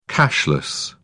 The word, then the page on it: cashless: British English